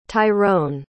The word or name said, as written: tyrone